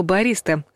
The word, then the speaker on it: бариста: Вспомним еще славное слово «бариста», означающее специалиста, который готовит кофе. А кофе, между прочим, тоже может быть с алкоголем. В нем ударение уже на втором слоге. Бариста.